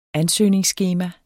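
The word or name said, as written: ANSØGNINGSSKEMA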